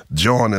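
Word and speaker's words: jawn: John is a slang terminology only used by Philadelphians.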